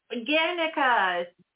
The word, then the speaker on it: Guernica: When are you going to see, I can't pronounce that word, it begins with a G, Guernica? – Guernica. – Guernica? I have no idea. – Hi. Guernica, Picasso's famous painting.